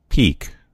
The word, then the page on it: peak: American English